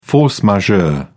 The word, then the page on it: force majeure: British English